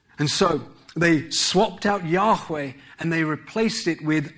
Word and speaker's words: Yahweh: And so no one knows exactly how the ancient name of God is pronounced. However, the general consensus is that it is pronounced Yahweh.